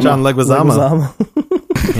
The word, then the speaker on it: Leguizamo: John how do you say his Leguizamo. – Name? – John Leguizamo.